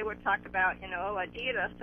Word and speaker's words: Adidas: So you can back up what Blake is saying, that you have relatives as well that pronounce the word Adidas. – Adidas. – Okay, where are your relatives from? – My mom was born in Austria. – Okay. – And see? – So every single one on that side of the family says Adidas. – No, yeah, Adidas, yeah. – Okay, all right, yeah, right, I'm still saying it wrong.